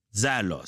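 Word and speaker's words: zélos (ζῆλος): c'est le mot zélos. Normalement, zélos, c'est ça la bonne prononciation.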